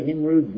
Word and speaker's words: Nimrud: It's spelled N-I-M-R-O-D. So they pronounce it Nimrod, because of modern English, but actually there should have been two O's, because his name is Nimrud, well known in the Semitic languages.